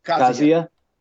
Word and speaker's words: Kazuya: Kazuya.